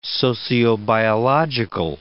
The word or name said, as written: sociobiological